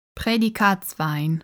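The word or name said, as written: Prädikatswein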